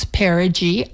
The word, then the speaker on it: perigee: So now, if ever I wonder how to pronounce perigee, I'll know. It sounds like pair of jeans.